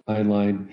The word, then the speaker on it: Heinlein: I guess I'm interested in the questions that were being asked, but I guess these kind of sacred cows that Heinlein, am I saying that right? Heinlein? – Yes, Heinlein.